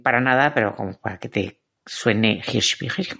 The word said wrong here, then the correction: Hirschbiegel: ¿cómo era? Hirschberger. Ah, bueno, pues es. O sea, es, como, o como tú lo pronuncias mejor, que es Oliver Hirschbiegel.